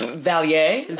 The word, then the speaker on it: Vallée: Vallée.